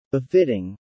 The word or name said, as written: befitting